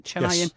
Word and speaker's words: Chennaiyin: So you've been over with Chennaiyin, is that how you pronounce it? Chennaiyin? – Yes. Yeah, that's it.